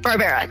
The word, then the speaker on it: Barbera: Going back to the, how do you pronounce it? Barbera? – Barbera, yep.